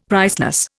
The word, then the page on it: priceless: Indian